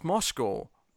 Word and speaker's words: Moscow: It even annoys me when I hear Americans say Moscow. It's Moscow. Moscow.